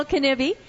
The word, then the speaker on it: Knibbe: Danielle Knibbe.